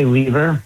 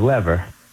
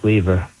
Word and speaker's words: Lever: I say Lever, but a couple generations ago, my grandfather said Lever. – So what do you prefer? – I like Lever, but okay.